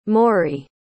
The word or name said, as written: morey